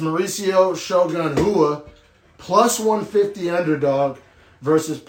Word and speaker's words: Rua: And yes, it's spelled Rua, R-U-A. In Brazil, they pronounce R's like Mexicans pronounce J's. So like Rua instead of Rua,